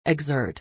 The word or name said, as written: exert